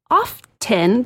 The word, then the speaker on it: often: It is often, the no T in the dictionary, it's often in the dictionary. The T is silent.